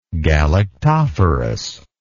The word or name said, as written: galactophorous